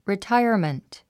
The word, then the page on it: retirement: ritáiə r mənt; リィタァイアメント